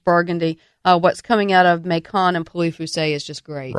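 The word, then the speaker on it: Mâcon: Macon? – That's the way you pronounce it in Georgia. – Absolutely. – Or Macon is the way it's closer to the way the French pronounce it.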